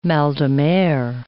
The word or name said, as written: mal de mer